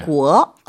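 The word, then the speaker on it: 구어 (国): 구어. – 구어. – 구어. – 구어. – 나라, 국자는 2성으로 발음합니다. 네.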